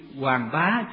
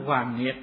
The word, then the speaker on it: Hoàng Bá: Mà cái ngọn núi chữ Hoàng Bá, chữ Bá này có chỗ đọc là Nghiệt nữa. Nói Hoàng Nghiệt nghe nó kỳ quá, cho nên dùng chữ Hoàng Bá nó thuận tai hơn.